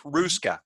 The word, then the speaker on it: Haruska: I mean, like Julie Haruska last week. Ruska, the H is silent.